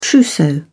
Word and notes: trousseau: British English

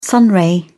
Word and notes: sunray: British English

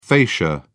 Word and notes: fascia: British English

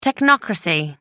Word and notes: technocracy: American English

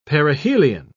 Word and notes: perihelion: British English

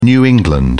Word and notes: New England: British English

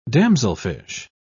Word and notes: damselfish: British English

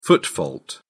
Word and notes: foot fault: British English

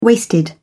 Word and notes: waisted: British English

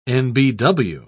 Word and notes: nbw: British English

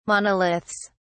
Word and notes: monoliths: British English

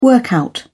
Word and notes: workout: British English

